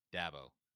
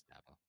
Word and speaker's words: Dabo: What do you call him? Dabo. Yeah, it's Dabo. Yeah. We had that conversation on Swain Event all the time because I said it wrong. It's not Dabo.